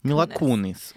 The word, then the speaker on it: Mila Kunis: Mila Kunis kanske det uttalas. – Mil, mi, Milan. – Milan. Mila. Nej, bara Mila. – Kunis. Mila Kunis.